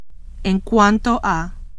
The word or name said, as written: en cuanto a